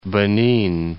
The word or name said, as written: Benin